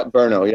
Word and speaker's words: Brno: How do we pronounce that? – Yeah, Brno, yep.